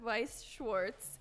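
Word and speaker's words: Weiß Schwarz: It's not just, it's, I think it's pronounced Weiss Schwartz.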